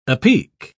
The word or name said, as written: apeak